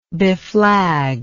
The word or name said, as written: beflag